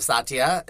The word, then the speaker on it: Satya: I am joined today by a very special guest, Satya. Am I saying it correctly? – Totally.